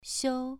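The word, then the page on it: xiu: tone 2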